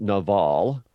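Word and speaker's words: Nabal: So in Hebrew, it would be pronounced Naval. And Naval is the exact same word that's used throughout the biblical narrative for fool.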